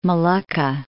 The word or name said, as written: Molucca